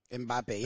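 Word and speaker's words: Mbappé: Or Randy Fund, P-F-U-N-D. Mbappe. What about Mbappe? Is that how you say his name? – Mbappe, yeah. – Mbappe. – Yeah, that's right.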